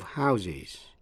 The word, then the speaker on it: house: A row of houses. Quý vị nghe lại. A house. A row of houses. Một dãy nhà. Với số nhiều houses, phát âm với âm is. To house, phát âm với âm z, là cho tạm trú hay là cấp nhà cửa.